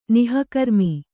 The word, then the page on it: ನೋಕರ್ಮ: nōkarma